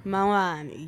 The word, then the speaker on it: Mangkhoane: That one is titled Mangkhoane. Am I saying it right? – Mangkhoane, yes.